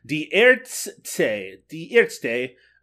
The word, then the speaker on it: Die Ärzte: Die Ärzte.